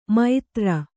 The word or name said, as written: ಮೈತ್ರ